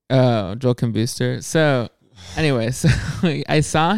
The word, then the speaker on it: Kim Booster: Was, and, the way you say his name, the whole time I thought it was Joel Kambusser. You pronounce vowels different than most people. Joel Kim Booster.